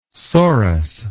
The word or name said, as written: sorus